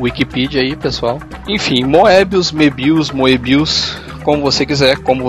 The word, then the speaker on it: Moebius: Eu vi ele pronunciando em documentários Mebius, bem a francesa. Ele o próprio Moebius falando Mebius, então.